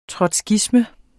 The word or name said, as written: trotskisme